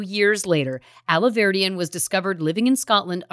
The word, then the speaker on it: Alahverdian: In 2019, Nicholas Aliverdian, is that how you say it? – You're doing great.